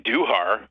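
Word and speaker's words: Gleyber Torres: Gleyber and Duhar, which is how he pronounces it.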